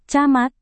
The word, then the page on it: camat: Indonesian